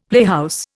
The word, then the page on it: playhouse: Indian